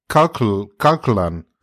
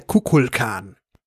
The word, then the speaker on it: Kukulkan: Der Kirk sagt irgendwie Kalkulan. – Ja, Shatner verspricht sich die ganze Zeit, das ist richtig. Der hat seine Dialoge separat aufgenommen und eigentlich heißt er Kukulkan. Und ja. Shatner sagt immer Kuklakan.